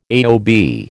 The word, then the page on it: AOB: American English